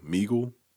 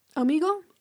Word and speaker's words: Omegle: Meagle. – Omegle.